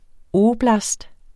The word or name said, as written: oblast